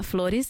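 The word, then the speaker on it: Flores: You can also find them on Instagram at Floris. So that's F-L-O-R-E-S.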